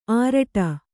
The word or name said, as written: ಆರಟ್ಟ